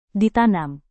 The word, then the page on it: ditanam: Indonesian